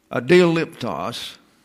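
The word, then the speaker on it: Adioliptos (ἀδιαλείπτως): Adioliptos, liptos is a long O there.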